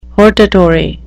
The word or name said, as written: hortatory